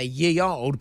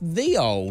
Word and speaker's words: Ye Olde: Today's fact of the day is that if you ever see Y-E space O-L-D-E written down, you might be inclined to say ye old, but it was always pronounced the old.